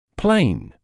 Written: plain

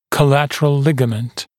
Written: collateral ligament

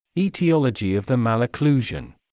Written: etiology of the malocclusion

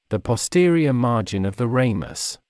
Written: the posterior margin of the ramus

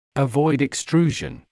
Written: avoid extrusion

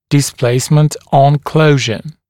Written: displacement on closure

